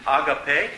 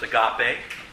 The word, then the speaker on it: agape: Now, the Greek word is agape, sometimes pronounced agape. Depends on what emphasis you put, what syllable you put the emphasis.